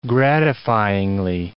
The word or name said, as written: gratifyingly